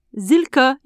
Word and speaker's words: Silke: Coucou, Zilka ici. Ça s'écrit S-I-L-K-E.